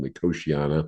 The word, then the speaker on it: Nicotiana: Nicotiana is the way it's spelled. Nicotiana is how I usually hear it pronounced.